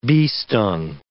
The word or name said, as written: bee-stung